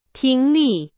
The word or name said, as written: 听力